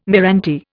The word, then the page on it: meranti: Indian